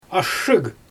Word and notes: ashshyG: Xyzyl

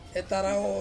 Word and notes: tarawo: Ansus

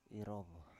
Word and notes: irov: Biak

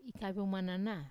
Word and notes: ikavio manana: Wamesa

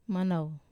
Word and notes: manau: wamesa

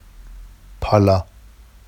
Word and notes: pɑlɑ: Vafsi

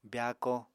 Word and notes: byaako: apatani

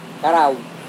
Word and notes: karawi: Serewen

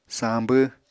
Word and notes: sañbw: Apatani

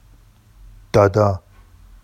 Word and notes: dɑdɑ: Vafsi

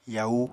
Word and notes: yau: apatani

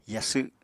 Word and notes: yasw: apatani

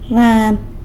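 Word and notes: rran: San Lucas Quiaviní Zapotec

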